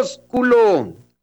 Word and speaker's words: ósculo: Se le dice ósculo. Con acento en la O.